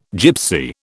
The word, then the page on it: gipsy: British English